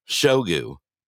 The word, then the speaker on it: Shoigu: Just to let you know, Russia's defense minister, Shoigu, anyway, how do you say it? You did it better than I do. – I believe it's Shoigu. – Shoigu, yeah, Ragu's brother, has arrived in North Korea.